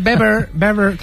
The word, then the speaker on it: Weber: Y está Manfred Weber, que se dice Weber, no se dice Weber, ¿vale? Se dice Manfred Weber.